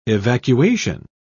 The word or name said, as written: evacuation